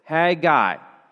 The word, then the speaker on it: Haggai: And yes, let me just say right from the very beginning, that is how you pronounce it, Haggai. All right? If you're wanting to think about it in a different way, or not a different way, or want to know how to pronounce it, you just think that horses need hay, and the person who gives the hay to the horses is the Haggai.